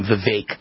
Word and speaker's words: Vivek: Vivek Ramaswamy, I think, or actually pronounce his name Vivek.